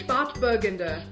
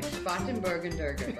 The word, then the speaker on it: Spätburgunder: Spätburgunder. – Spätburgunder. My problem with pronouncing German words is I always add extra syllables, so I probably would have said that like Spätburgunder.